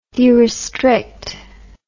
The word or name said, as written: derestriction